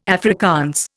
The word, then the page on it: Afrikaans: Indian